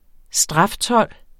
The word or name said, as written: straftold